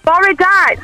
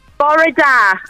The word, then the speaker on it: bore da: Bore da. Welsh for good morning. – Oh, how do you say it? – Bore da. – Thoriga. – No, bora. – Oh, bora. – Da.